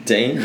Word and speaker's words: Dean: Dean. – Just D-E-A-N.